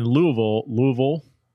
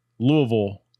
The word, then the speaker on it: Louisville: I never can say it right. Louisville. That's how you say, whenever I hear people from Louisville say Louisville, they say Louisville. And then when I say Louisville, they say, nah, it's close.